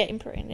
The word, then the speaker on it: Empyrean: Yeah, Empyrean, yeah.